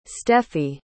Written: steffi

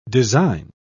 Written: design

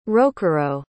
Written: rokuro

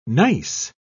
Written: gneiss

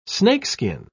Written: snakeskin

